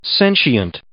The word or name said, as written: sentient